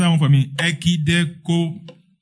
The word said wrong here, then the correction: ekdechomai: Huh? Wow, you have, there's no idea. Okay, ekdekomai. Okay, thank you. As waited, it means expecting. Ekdechomai.